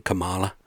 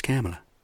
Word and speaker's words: Kamala: I got to learn to say her name correct, too, because I was saying Kamala. I didn't know. Shit, man. But I know now. It's Kamala. Congratulations.